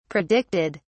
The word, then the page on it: predicted: British English